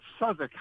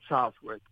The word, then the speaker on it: Southwick: One of the chapters refers to the invasion of the European continent, and it's about a mansion called Southwick House. We might call it Southwick, but it's pronounced Southwick House, and it's in Portsmouth.